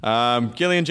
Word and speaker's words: Gillian: Gillian Jacobs, hard G, soft J. I know all J's are soft, but I just feel like it's the way you're supposed to.